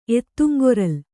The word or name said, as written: ಎತ್ತುಂಗೊರಲ್